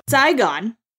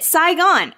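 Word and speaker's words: Sigh Gone: Psy Gone. – Oh, I get it. Psy Gone. – Okay, so the book is spelled S-I-G-H.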